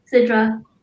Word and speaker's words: Sidrah: Sidrah.